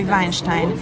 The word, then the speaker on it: Weinstein: Ez olyan, mint a vizé, Harvey Weinstein is mi Weinsteinnak mondanánk, mert a németek ez egy német név, és akkor azt mondanák, hogy Weinstein.